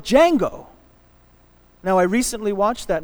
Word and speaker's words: Django: The D is silent, Django, and he's on a horse.